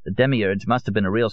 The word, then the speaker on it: demiurge: How do you say it? – You mean the demiurge? – That's him, yes.